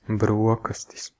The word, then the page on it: breakfast: /ˈbɹɛk.fəst/; American English; [ˈbɹɛk.fɪst]; British English; [ˈbɹɛk.fəst]; /ˈbɹekfəst/